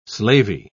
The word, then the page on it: slavey: British English